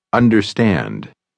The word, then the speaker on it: understand: Você ouviu o som do R americano na palavra understand? O R americano nunca se pronuncia como R em português.